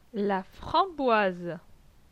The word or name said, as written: La framboise